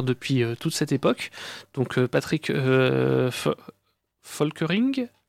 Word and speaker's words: Patrick Volkerding: Patrick Volkerding. On va le prononcer comme ça.